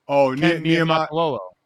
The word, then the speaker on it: Niumatalolo: Ken Niumatalolo.